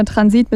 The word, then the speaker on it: Transit: Ich sage immer Transit mit der Betonung auf dem I und hoffe, dass es stimmt.